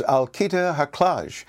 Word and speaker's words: Alketa Haklaj: Vice President of Digital Marketing and Communications for DocNow is Alkita Haklaj.